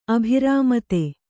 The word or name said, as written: ಅಭಿರಾಮತೆ